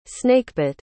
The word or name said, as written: snakebit